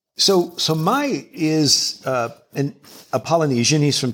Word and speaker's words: Mai: I don't know if I'll get the pronunciation right, but it's Mai. Is that how to pronounce it? – Mai. – Mai.